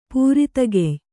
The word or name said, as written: ಪೂರಿತಗೆಯ್